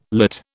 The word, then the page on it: lit: British English